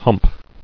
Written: Humped

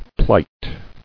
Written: Plighter

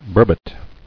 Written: Burbot

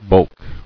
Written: Bulking

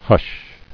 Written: Hushed

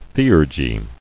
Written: Theurgist

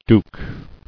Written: Douc

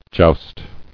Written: Joust

